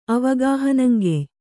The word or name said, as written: ಅವಗಾಹನಂಗೆಯ್